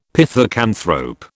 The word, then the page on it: pithecanthrope: British English